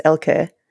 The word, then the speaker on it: Elke: The only person who comes close to the more German pronunciation is Miss Piggy, who says Elke.